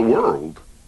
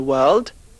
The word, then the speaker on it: world: Don't pronounce the R in world and the E in village. – There are five continents in the world.